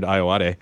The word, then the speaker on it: Ayoade: Sitting to Jonah Ray's immediate right is Richard Ayoade. Please tell me I said that correctly. – Yeah, no, it's good.